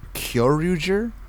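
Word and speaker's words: Kyoryuger: I didn't even try, because like I gave up around Kyoruger. And then I finally, you're saying it right.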